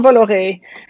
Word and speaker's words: Bolloré: Is it Vincent Bolloré? – Vincent Bolloré.